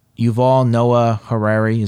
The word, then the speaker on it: Yuval Noah Harari: Cause, Yuval, am I getting his name right? Yuval Noah Harari, is that his name? – Close enough, yeah.